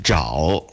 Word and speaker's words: Zhao: Si dice Zhao, ovviamente poi dipende dal tono. – Come fosse una G.